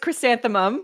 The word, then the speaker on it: chrysanthemum: Chrysanthemine. Am I saying that right? – I think I'm going to give it to you. It's chrysanthemum.